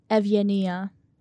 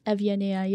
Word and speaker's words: Evgenia: Evgenia. – Evgenia? – Evgenia, yeah. It's a Greek name.